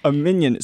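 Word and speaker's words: minyan: a minion. So that's how I always heard it pronounced growing up. – I think that's the Yiddish pronunciation.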